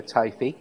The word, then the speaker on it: Teifi: Teifi over there in Cardiganshire.